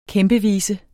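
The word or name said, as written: KÆMPEVISES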